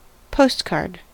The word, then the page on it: postcard: American English